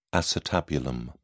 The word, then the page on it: acetabulum: British English